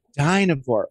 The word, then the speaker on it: Dynevor: Well, I need your help with the pronunciation of the diamond of the season's last name. Phoebe Denver? – Dinovore. – Ooh, that sounds so pretty. – It even sounds like diamond.